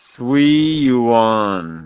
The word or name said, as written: suiyuan